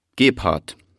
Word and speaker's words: Gebhardt: Gebhardt. Hans-Dieter Gebhardt. Das schreibt man G-E-B-H-A-R-D-T. – Also G-E-B-H-A-R-D-T.